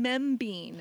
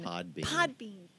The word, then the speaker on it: Podbean: And you can catch Yolando's back catalog of messages on the Derrida Church podcast, which is on Membean. – Podbean.